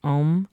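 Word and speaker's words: Ogham: And when I first started, I had started studying Om, which is the language of trees, not the O-H-M. – No, no, I know what you're talking about. – Yeah. It's spelled O-G-H-A-M, I believe.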